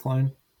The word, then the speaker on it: Quine: Like distantly related, but apparently like Robert Quine is his uncle or something. And then you mean Robert Quine? – Who did I say? – No, you said it right.